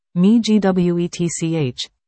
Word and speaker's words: Miigwech: Miigwech, in his language, Nkawe, which is part of the Algonquian language family.